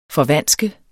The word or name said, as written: forvanske